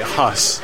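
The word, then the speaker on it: Haus: It's called Burning Down the Haas. I got the German right, Huss.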